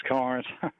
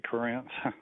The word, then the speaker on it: Corinth: Yeah, that's how I pronounce this, Corinth. But a lot of people pronounce it Corinth.